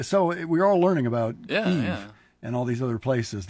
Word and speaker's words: Kyiv: And well, why do I say Kyiv and not Kiev? Because Kyiv is the Ukrainian pronunciation.